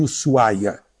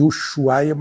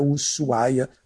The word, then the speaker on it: Ushuaia: Ushuaia, como dizem os argentinos. A gente chama de Ushuaia, mas é Ushuaia a pronúncia argentina.